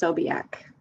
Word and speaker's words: Sobiak: It's very Polish and gets mispronounced all the time, so it's pronounced Sobiak. – Sobiak. I was going to say Sobiek. I was very close. – Either way is fine.